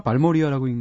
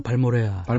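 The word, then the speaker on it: Balmorhea: 자 노래도 굉장히 좋아하시는 것 같은데 어 이거 어떻게 읽나요? 발모리아라고 읽나요? 발모레아. 발모레아 그렇게 예. 읽는 거군요.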